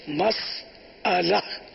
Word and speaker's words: مسئلہ: یہ بھی آپ کو تعجب ہوگا کہ یہ کیا بول دیا, تو مسئلہ صحیح تو ہے, مسئلہ صحیح نہیں ہے, صحیح تلفظ ہے مسئلہ, میم سین زبر مس پھر حمزہ پر زبر, حمزہ زبر اور لام ہا مسئلہ.